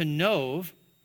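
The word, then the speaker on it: Nov (נֹב): Notice it says, then David came to Nov, that's how you say it, by the way, in Hebrew, to Ahimelech the priest.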